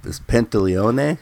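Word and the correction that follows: Pantoliano: I can't. Is that how you say his name? – Pantaleano, yeah. – Pantaleano.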